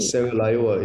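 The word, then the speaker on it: Serra Leoa: Sierra Leoa, it's the Portuguese word for Sierra Leone, because I was trying to show that we don't even own our name.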